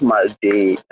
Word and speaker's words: Mark Day: And I think we just had somebody new join us, Mike D. Hopefully I pronounced that right. Would you like to introduce yourself? – So what's up, people? Good morning. My father is very morning. My name is Mark Day.